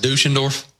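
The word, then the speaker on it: Düsseldorf: It's Dusseldorf Jr. Dusseldorf. You think that's pronounced Dusseldorf? – It is pronounced Dusseldorf.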